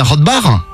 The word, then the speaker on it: Rothbach: Je crois que je le prononce bien. Bonjour Aurélie. – Bonjour. – On dit bien Rothbach? – Oui.